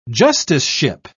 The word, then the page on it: justiceship: British English